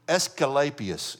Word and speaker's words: Asclepius: Asclepius is how you pronounce it.